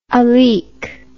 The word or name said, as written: aleak